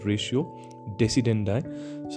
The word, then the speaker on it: ratio decidendi: R-A-T-I-O, ratio decidendi, D-E-C-I-D-E-N-D-I. So it consists of two words and it is pronounced as ratio decidendi.